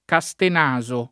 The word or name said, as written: Castenaso